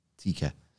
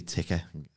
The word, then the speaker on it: tikka: Tikka. – The right way to say it, and I used to say ticker.